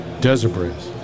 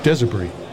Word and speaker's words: Desabris: Desabris. – Say that again? – Desabris. – The S is silent.